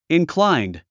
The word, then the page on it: inclined: inkláind; インクラァインド